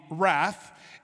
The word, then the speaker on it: wrath: So as you can see, the W is silent on the wrath.